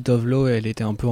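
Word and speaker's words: Tove Lo: Donc ça s'écrit T-O-V-E plus loin L-O et ça se prononce Tovelu.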